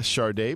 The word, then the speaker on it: Sade: Yo nunca he entendido por qué se pronuncia el nombre de esta artista, Sade, pero así se pronuncia.